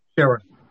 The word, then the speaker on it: Scherer: Stephen Shera, S-C-H-E-R-E-R. – Mr. Scherer. Scherer, how do you pronounce it? – Scherer. – Oh, Mr. Scherer, you sound better now where you're located.